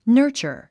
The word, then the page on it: nurture: nə́ː r tʃə r; ナーチャァ